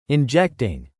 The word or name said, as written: injecting